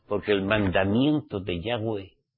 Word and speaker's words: Yahweh: hay que pronunciar su nombre, Yahweh, no YHWH como hacéis muchos, esos son siglas.